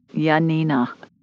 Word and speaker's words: Yanina: Say it again. – Janina? – Spell it for me. – Y-A-N-I-N-A. – Okay. Janina.